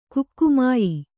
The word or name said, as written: ಕಾಗೆಮಾಯಿ